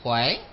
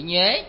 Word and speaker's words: nhuế: Nhược túng nhuế tâm, cái chữ này cũng có các vị cũng có thể đọc là chữ khỏe ha. Cũng là chữ nhuế, chữ khỏe. Nhưng mà chúng ta quen nhuế rồi ha.